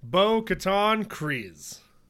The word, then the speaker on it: Bo-Katan Kryze: So that brings us into this week's character, Bo-Katan Kryze.